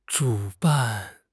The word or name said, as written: 主办